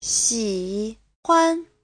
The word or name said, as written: xi3huan5